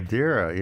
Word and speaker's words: Madeira: Am I pronouncing that right? – Madeira wine? Madeira, yeah.